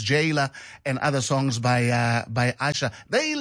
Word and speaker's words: Asa: Again, Asha, written A-S-A, but pronounced Asha, not Asa, as some people would think. Because as I said, the S in Yoruba is pronounced as sh. So Asha.